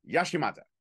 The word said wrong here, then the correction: Yamashita: Yashiata. – Yamashita. – There we go.